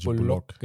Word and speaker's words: Bullock: habe ich ihn richtig ausgesprochen? – Ja, Bullock, Bullock, genau, ja.